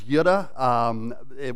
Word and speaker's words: Lydda: The first of these encounters takes place in the town of Lydda. It's actually, I think, more properly pronounced as Yudda, which sounds kind of like Yoda, and we're not going to walk down that road this morning.